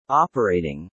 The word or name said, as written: operating